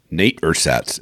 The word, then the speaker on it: Ersatz: Nate Ersatz and the Skinwalkers. – Yep. – Okay. – That's how it's pronounced. – Nate Ersatz and the Skinwalkers.